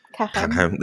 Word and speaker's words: Carême: Carême.